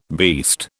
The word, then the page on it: beast: British English